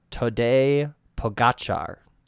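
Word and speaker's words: Tadej Pogačar: Okay, I've just watched hours of YouTube footage and how to pronounce his name, and it's Tadej Pogacar. That's how you say it.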